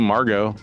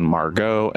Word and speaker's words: Margot: margot is the french diminutive of marguerite hence the pronunciation margot and not margot uh, which means pearl Margot shows hmm.